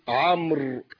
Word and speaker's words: عمرو: كذلك كلمة عمرو، هذه الواو تكتب ولا تنطق حتى يفرق بينها وبين عمر،